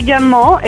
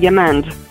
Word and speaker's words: Gamand: Sophie Gamon is the French pronunciation, but you can call me Sophie Gamand, and I'll be fine with that.